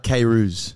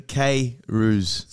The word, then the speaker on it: Kayrouz: How do you pronounce your last name? – It's John Bernard Kayrouz. – Kayrouz. – Yeah, so phonetically Kayrouz.